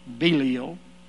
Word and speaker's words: Belial: Or some of you would pronounce it Belial.